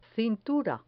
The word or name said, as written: cintura